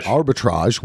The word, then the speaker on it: Arbitrage: Arbitrage with Richard Gere and The Company You Keep.